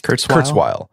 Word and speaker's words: Kurzweil: Kurzweil.